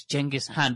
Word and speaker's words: Genghis Khan: If you look at, Genghis Khan, for example, or I'm told the correct pronunciation is Genghis Khan.